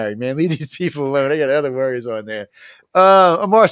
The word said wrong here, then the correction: Amir: say your name for me, because I'm probably saying it wrong. – You're good. It's Amir. Amir Agoora. Amir, okay, see? – Yeah.